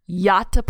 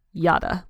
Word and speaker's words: Yatta: Yatta play this. Yatta, like you ought to.